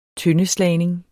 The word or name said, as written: tøndeslagning